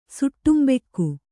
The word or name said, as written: ಸುಟ್ಟುಂಬೆಕ್ಕು